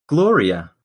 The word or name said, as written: gloria